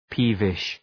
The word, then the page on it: peevish: ‘pi:vıʃ